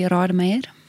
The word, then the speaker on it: Rademeyer: In English or in Afrikaans? – Tell us both. – Well, in Afrikaans, it would be Rademeyer.